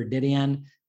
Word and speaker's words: Dedeyan: So the best way to reach me on every single social platform, I'm at Christopher Dedeyan. My family name, you spell it D-E-D-E-Y-A-N.